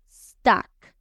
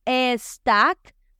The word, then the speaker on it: stuck: Stack. Otra vez, la palabra empieza con S. No vamos a decir e stack, sino que vamos a decir S.